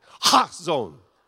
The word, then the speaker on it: chazon: Yeah, to really do it right, the C is silent, and you bring it right, you bring it from, you know where you bring it from. You know that, ha, you know that sound? It's that, chazon.